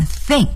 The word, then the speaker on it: think: 再来学习另外一个动词，如果我们说我想怎么怎么样这个我认为怎么怎么样，这时候呢要用 think, T-H-I-N-K, think. 注意这里又涉及到 TH 的发音，我们一定要把这个舌尖伸在上牙齿和下牙齿中间进行摩擦 think.